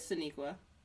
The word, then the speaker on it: Sonequa: Yeah, it is Saniqua. Saniqua Martin Green. But it's spelt uniquely, so Sonequa Martin-Green.